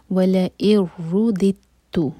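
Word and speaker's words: वला इरुदितु (ولئن رددت): तो हम फर्स्ट दाल को जिसके नीचे कसरा है उसको ता से जोड़ेंगे, बीच में जो दाल है उसको प्रोनाउंस नहीं किया जाएगा. तो ये होगा वला इरुदितु.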